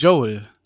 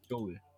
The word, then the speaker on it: Joel: heißt der Joel oder wie spricht man den aus eigentlich? Joel. Joel, okay.